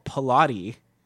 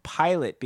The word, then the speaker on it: Pilate: Now we got, okay, how do you pronounce this next band's name? Because I want to say they're called Pilati. – Mm-mm. – But I think they're called Pilot because they were forced to change their name.